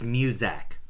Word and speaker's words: Muzak: If you just say, I'm going to be an elevator music composer for the rest of my life, you're pronouncing it wrong. It's Muzak.